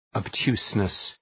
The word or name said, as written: obtuseness